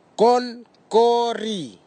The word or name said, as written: konkori